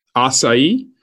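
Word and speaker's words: açaí: Acai, pitaya.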